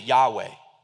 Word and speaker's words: Yahweh: It's four consonants, but we pronounce it Yahweh.